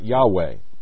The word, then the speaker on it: Yahweh: The pronunciation can never be certain, since early Hebrew had no vowels, only consonants. Though evidence from several sources, such as early Greek transliterations, point to the pronunciation as Yahweh.